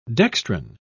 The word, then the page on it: dextran: British English